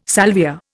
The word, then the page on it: salvia: Indian